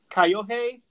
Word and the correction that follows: Kyohei: Yamani? No, go ahead, Jake, take it. – Hold on, I'm looking, oh, Kyohei Yamane. – Yamane. – Kyohei.